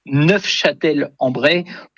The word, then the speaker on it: Neufchâtel-en-Bray: Et en effet, pour les puristes, dont je ne suis pas, puisque je n'ai pas prononcé le F, pour les puristes, en effet, il faut prononcer Neufchâtel-en-Bray pour, en effet, peut-être se différencier de la ville de nos amis suisses.